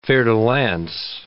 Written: fer-de-lance

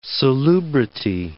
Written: salubrity

